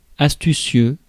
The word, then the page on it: crafty: [ˈkræf.ti]; /ˈkrɑːfti/